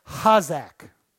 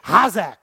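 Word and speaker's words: hazak (חזק): The word strengthened in the Hebrew is the word hazak. You'll see it on the screen. You don't pronounce the C and the H. You got to come from the back of your throat real deep. Ha, hazak.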